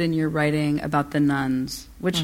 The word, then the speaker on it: nones: N-O-N-E-S. – Yeah. Am I pronouncing it right? – No, you are. Just when you say it, people think you're talking about Catholic nuns.